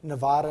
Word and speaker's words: Nevada: The first question actually comes from an AFSCME member, Deborah Frenzy of Reno, Nevada, and she says, she's got a lot. – I think you may have mispronounced the word. – If I mispronounce it, I'm sorry. – It's Nevada, George.